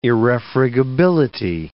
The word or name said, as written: irrefragability